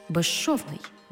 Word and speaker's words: безшовний: У словах безшовний і безшумний, свистячий з плюс шиплячий ш всередині слова разом вимовляємо як жи-ши, безшовний, безшумний.